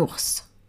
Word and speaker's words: ours: Eh bien parce que il y a un S à la fin du mot ours et on prononce ce S. Normalement, en français, on ne prononce pas le S à la fin d'un mot.